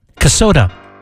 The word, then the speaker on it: Kasota: And Tara from, help me out, Kasota. – Rhymes with Minnesota. – Oh, yeah. Nice. From Kasota, Minnesota.